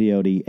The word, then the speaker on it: peyote: It starts, and they're all in the peyote, and am I saying it right? – Yeah, peyote.